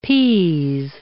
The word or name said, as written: p.